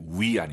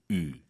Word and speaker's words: 雨: 그 비오다 할 때, YU 발음이요. 예, 위 아니고, 유.